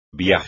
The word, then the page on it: viaje: espagnol